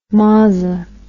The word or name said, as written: mozza